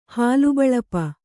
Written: ಹಾಲುಬಳಪ

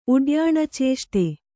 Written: ಉಡ್ಯಾಣಚೇಷ್ಟೆ

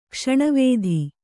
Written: ಕ್ಷಣವೇಧಿ

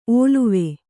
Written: ಒಲವು

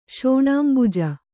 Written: ಶೋಣಾಂಬುಜ

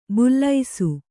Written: ಭುಲ್ಲೈಸು

